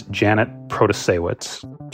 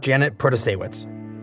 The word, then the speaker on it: Janet Protasiewicz: And the liberal candidate is a Milwaukee County judge, and you're going to have to practice saying her name with me. It's Janet Protasiewicz. – Janet Protasiewicz. – Protasiewicz. Her early ads in the race taught voters how to say her name.